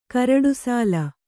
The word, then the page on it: ಕರಡುಸಾಲ: karaḍusāla